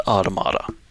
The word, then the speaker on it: Automata: The game officially calls it Automata. – Automata? – So, if you want to go by the actual game, that is the correct pronunciation, but I will always say Automata.